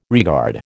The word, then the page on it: regard: American English